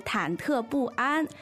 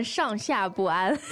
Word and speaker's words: 忐忑不安: 对，第二个呢是忐忑不安。 – 忐忑不安，小的时候读成上下不安。